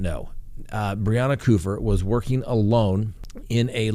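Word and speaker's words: Kupfer: The name is spelled K-U-P-F-E-R. I watched four pronunciations of the name, but the news organization that interviewed the family pronounced it Kufer. So I believe they're the best way to make sure we get the pronunciation correct.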